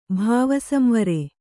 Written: ಭಾವಸಂವರೆ

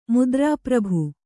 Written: ಮುದ್ರೆಪ್ರಭು

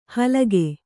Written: ಹಲ್ಕ

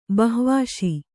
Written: ಬಹ್ವಾಶಿ

